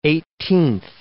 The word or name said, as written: eighteenth